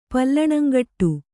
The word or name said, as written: ಪಲ್ಲಣಂಗಟ್ಟು